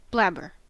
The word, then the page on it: blabber: American English